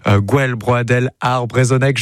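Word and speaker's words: Gouel Broadel ar Brezhoneg: Gwelbrodel ar Brezhoneg, je l'ai bien prononcé ? – Parfaitement.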